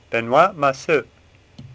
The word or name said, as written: Massou